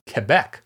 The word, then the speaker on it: Quebec: But it's actually pronounced slightly differently. It's Quebec. In the correct military use of the NATO phonetic alphabet.